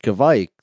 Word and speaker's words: Kvike: And the guy's like, no, it's pronounced Kvike. That's why it's Kvike Like Mike.